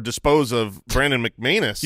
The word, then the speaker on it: McManus: When you have anus, you got to rhyme with it. Yeah. That's what I've always been taught. – And from now on, it is Brandon. – Oh no, McManus.